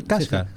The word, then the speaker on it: Kashgar: ¿En qué año fue editado por primera vez La bailarina de Kashgar? ¿Se dice así? ¿Kashgar? – Sí, esas son unas mesetas de la India.